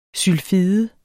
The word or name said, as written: sylfide